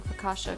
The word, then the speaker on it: focaccia: Hey, I'm proud of you for pronouncing focaccia correctly.